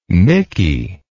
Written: micky

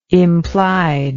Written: implied

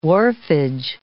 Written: wharfage

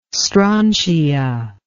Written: strontia

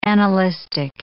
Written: annalistic

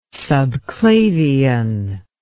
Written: subclavian

